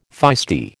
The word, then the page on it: feisty: American English